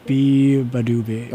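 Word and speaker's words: Beabadoobee: No, how do you actually pronounce it? It's Biba what? – Biba... I don't know. – Biba Doobie? – Is it Biba Badoobie? – Biba Badoobie. – Be-ba-doobie. – Oh, Be-ba-doobie.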